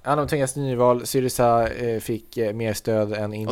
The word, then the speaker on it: Syriza: Hur uttalar man den på svenska? – Syriza. – På svenska? – Ja, men whatever. Ja. Med svensk dialekt. Ja. Syriza.